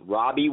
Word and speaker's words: Robby: As always, always Robby with a Y like yo.